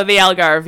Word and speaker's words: Algarve: Algarve, Portugal? – Oh, the Algarve, yeah.